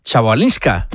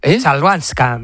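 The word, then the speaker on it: Chwalińska: Es complicada, ¿eh? Chabalinska. – Chalbanska.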